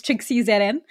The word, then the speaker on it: TrixieZN: It's TrixieZN. I say it's Trixie with a silent ZN because the ZN is like just added on.